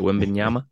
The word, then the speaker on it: Wembanyama: I'm going to say that right. Wembanyama.